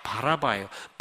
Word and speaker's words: 바라바: 발음을 잘 하셔야 됩니다. 바나바가 아니라 바라바예요.